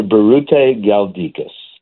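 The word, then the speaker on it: Biruté Galdikas: Dr. Berute Galdikas.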